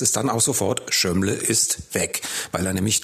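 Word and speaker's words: Zsömle: Schömle schreibt man Z-S-Ö-M-L-E.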